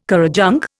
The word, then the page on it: currajong: Indian